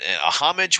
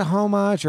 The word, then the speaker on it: homage: Is it a homage or homage? – Most Americans pronounce it homage, but if I recall, the French pronunciation is you can the H can be silent like homage.